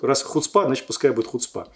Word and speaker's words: хуцпа: Написали мне: мы живем в Израиле, вы неправильно слово произносите. Не хуцпа, а хуцпа.